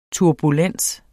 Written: turbulens

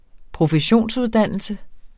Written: professionsuddannelse

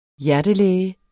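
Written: hjertelæge

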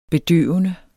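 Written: bedøvende

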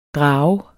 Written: drager

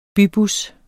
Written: BYBUSSER

